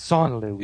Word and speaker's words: sans limite: Sans limite. Actually, see, I messed it up. It's sans limite. – Yeah, you don't pronounce the S.